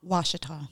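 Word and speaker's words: Ouachita: uh, probably Caddo, but they pronounce it Washita now.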